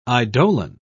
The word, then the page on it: eidolon: British English